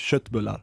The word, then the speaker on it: köttbullar: Wie spricht man es denn richtig aus? – Ja, du sagst das richtig. Ich weiß nicht, ich probiere das Deutsch zu sagen oder etwas, aber das heißt eigentlich Schöttbullar.